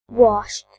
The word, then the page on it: washed: British English